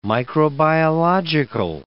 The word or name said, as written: microbiological